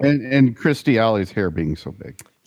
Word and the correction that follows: Kirstie: Yeah, her hair was a lot of Kirstie.